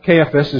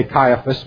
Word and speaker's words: Caiaphas: In John chapter 18 and verse 14, the text tells us about Caiaphas, is how I like to pronounce his name. Others say Caiaphas,